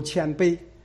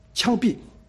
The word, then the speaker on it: 谦卑: 他那天去要讲道呢，他要讲一个温柔谦卑，好，这个谦卑他发音成什么呢？谦卑，枪毙，枪毙。